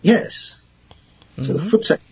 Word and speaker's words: voetsek: And it's spelled V-O-E-T-S-E-K, but it's pronounced like your feet, like footsack? – Yes, because the V in Afrikaans is pronounced as an F. – Oh, okay. – So, footsack. And double O in English is O-E in Afrikaans. So it is footsack.